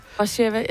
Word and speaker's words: Warszawa: Warsaw, eller Warsaw, hvis du vil have den engelske udtale af Warszawa. – Warszawa, ja.